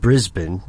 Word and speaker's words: Brisbane: Melbourne and Brisbane.